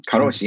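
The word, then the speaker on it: Karoshi (過労死): Karoshi, yes.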